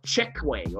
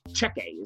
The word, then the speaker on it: Checquy: She gets more of the story from herself, and she's told about this group of people called the the Chekwang or Checquy. Checquy is how they say it.